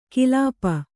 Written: ಕಿಲಾಪ

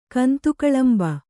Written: ಕಂತುಕಳಂಬ

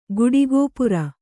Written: ಗುಡಿಗೋಪುರ

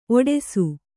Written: ಒಟ್ಟೈಸು

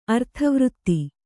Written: ಅರ್ಥವೃತ್ತಿ

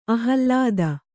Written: ಆಹ್ಲಾದ

